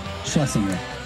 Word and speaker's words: Schlesinger: And Adam, how do you pronounce Adam's name? Last name? – Schlesinger.